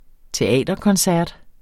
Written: teaterkoncert

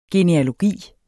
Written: genealogi

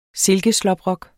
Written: silkeslåbrok

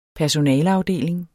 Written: personaleafdeling

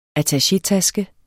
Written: ATTACHETASKEN

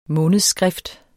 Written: månedsskrift

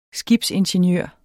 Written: skibsingeniør

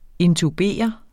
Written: intubere

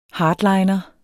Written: hardliner

